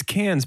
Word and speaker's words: Cannes: It's Cannes, but now I want a chicken festival that's I'm sorry, also a film Cannes.